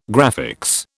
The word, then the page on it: graphics: British English